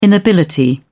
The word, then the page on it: inability: British English